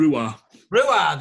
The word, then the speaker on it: Ruadh: And the album that I listened to and the artist that I listened to were Ruda. Have I said that correctly? – No, it's okay. Rua, there we go.